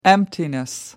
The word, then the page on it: emptiness: American English